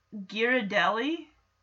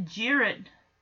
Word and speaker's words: Ghirardelli: Ghirardelli? Ghirardelli. I've always pronounced it Girard. Ghirardelli. I don't know why. I don't know why I thought the H was silent.